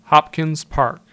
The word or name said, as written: Hopkins Park